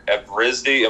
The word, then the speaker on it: Evrysdi: And then with the Risdiplam or the Evrysdi, am I pronouncing that correctly? – Yes, yeah.